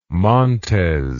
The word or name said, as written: Montez